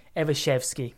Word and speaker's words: Evashevsky: Forrest Evenchki, right? Oh, sorry, let me say it again. Evashevsky.